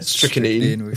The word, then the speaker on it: strychnine: Strychnine.